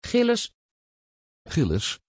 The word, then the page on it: Gilles: Dutch